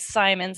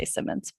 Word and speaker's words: Symonds: My last name is Symonds. It rhymes with diamonds. I always had to tell the announcers that so they could remember, because everyone wants to say Symonds.